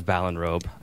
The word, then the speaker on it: Ballinrobe: Um, so I was fascinated by your journey out of Ballinrobe. I hope I'm. Am I pronouncing that right? That's right. Yeah.